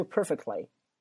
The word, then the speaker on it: perfectly: 我举个简单例子，你去买衣服，你买裙子，我这个裙子穿你身上很合适啊，叫 this dress fits you perfectly， this dress fits you perfectly。注意就 perfectly，那个 perfect 那个 t 也没发音吧，直接是 perfectly This dress fits you perfectly，这个裙子超级适合你，完美，对吧。我再举个例子，你去商场里面买衣服说这个衣服不合身儿，不合身儿怎么讲？It doesn't fit, it doesn't fit 就不合身儿。